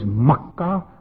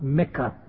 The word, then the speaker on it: Makkah: The correct pronunciation and the correct word is Makkah Mukarrama. It's not Mecca.